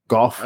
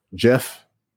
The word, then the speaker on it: Geoff: How do you say Geoff? G E O F F. Golf. I just call him like golf. Jeff. Was it like golf. – Jeff? Jeff. – It might be Jeff.